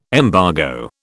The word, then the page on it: embargo: British English